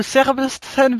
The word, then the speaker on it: Sebastian: Une victoire de Sebastian Vettel? – Ah non, j'ai bien dit Sébastien Vettel. – Non, tu réécouteras bien le SAV, mercredi, tu verras, t'as dit Sébastien. Attention, t'es en train d'écorcher le nom du futur simple champion du monde.